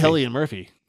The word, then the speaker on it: Cillian: Cillian Murphy. – Yes. – As you would pronounce it in the Irish.